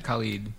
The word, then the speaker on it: Khaled: It's Khaled, right? – Khalid. – Is it? – Mm-hmm.